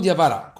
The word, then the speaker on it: Diawara: Eh sì, ha vinto 3-0 in tribunale perché, spieghiamolo per chi non avesse sentito questo caso, il giocatore della Roma Diavara, oh, si, si, pronuncia così? – Amadou Diavara, confermo.